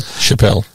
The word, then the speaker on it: Chappelle: Chappelle. Dave Chappelle. – Is that how you say it? – Indeed. – So when I say Chappelle, that's wrong.